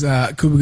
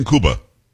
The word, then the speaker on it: Cuba: Why are you saying Cuba? – Huh? – It's Cuba. It's not Cuba Gooding Jr. It's definitely Cuba. Okay. He pronounces like the country.